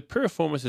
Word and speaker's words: piriformis: 10 piriformis stretches. I'm not sure I'm saying that correctly. – Piriformis stretches.